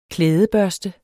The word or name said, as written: klædebørste